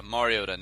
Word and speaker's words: Mariota: Mariota, not Mariota.